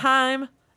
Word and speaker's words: Haim: With Haim, it rhymes.